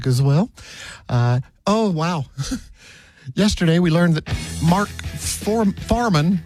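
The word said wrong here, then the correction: Fuhrman: how do you say his name? Farman? That guy from the O.J. Simpson trial. – Yeah, the cop, right. – Yeah, he died. – Mark Furman.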